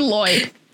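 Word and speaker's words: Loie: How do you pronounce a name that's L-O-I-E? – Loy. – I'll go with Loy.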